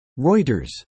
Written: Reuters

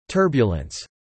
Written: turbulence

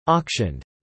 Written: auctioned

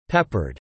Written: peppered

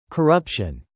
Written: corruption

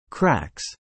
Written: cracks

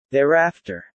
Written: thereafter

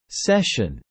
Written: cession

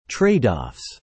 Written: tradeoffs

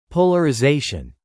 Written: polarization

